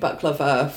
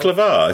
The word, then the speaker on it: baklava: Do you know, I made baklava for my whole class when I was 12. – Baklava? Is that how you pronounce it? – Baklava. – I thought it was baklava. – No, it's baklava.